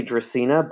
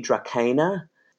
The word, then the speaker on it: Dracaena: In English, you would say Dracaena, but the actual Latin would be Dracaena. So the C's in Latin are always hard. It's always a hard C.